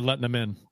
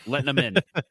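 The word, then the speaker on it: Lehtonen: You pronounced Letton's name wrong. – It's Kari Lehtinen. – Lehtinen. Yeah.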